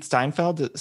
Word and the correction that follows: Stanfield: Stanfield. – Stanfield.